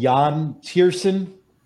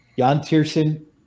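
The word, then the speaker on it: Yann Tiersen: The artist is called Jan Tiersen. Ooh. It's spelled Y-A-N-N, and the last name is Tiersen, T-I-E-R-S-E-N, Jan Tiersen.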